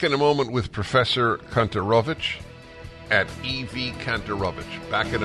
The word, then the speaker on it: Kontorovich: K-O-N-T-O-R, Kantorovich, Kantorovich.